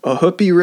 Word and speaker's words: Ohoopee: Uh, the song is called Ohoopee River Bottomland.